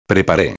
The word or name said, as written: prepare